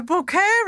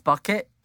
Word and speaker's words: Bucket: And she says, oh, the bouquet residence. And it's Bucket. It's Bucket. And she goes, it's a bouquet. It's the French pronunciation.